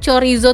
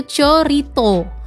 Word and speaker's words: chorizo: Terus juga pengucapan dari chorizo tadi nih teman pradua adalah yang benar ternyata ya, yaitu chorito. Lain ya. Itu di Spanyol. Jadi namanya chorito. Kemudian kalau di Amerika Serikat namanya chorizo.